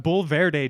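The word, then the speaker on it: Bulverde: That's a competition barbecue team, excuse me, from Bull Verde, Texas. I hope I'm pronouncing that right. It's just out of San Antonio. – Yeah, it sounds right to me.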